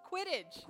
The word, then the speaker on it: Quidditch: Kidditch. Quidditch. Quidditch. – Sorry, I said it wrong.